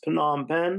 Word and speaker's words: Phnom Penh: Um, please go check out his new book from Charleston to it's Phnom Penh.